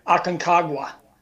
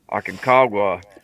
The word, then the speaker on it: Aconcagua: what is uh, Aconkagua? Aconcagua. Aconcagua.